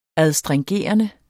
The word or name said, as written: astringerende